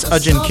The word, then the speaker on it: Eojin: That's Ajin Kim. It's spelled E-O-J-I-N. Kim, K-I-M, Hamilton.